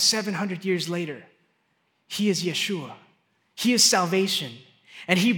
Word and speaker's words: Yeshua: That word, salvation, in the Hebrew, is a word, and it is pronounced Yeshua. Yeshua. This was written 700 years before Jesus came. Do you know what Jesus' name is in Hebrew? It's not pronounced Jesus. It's pronounced Yeshua.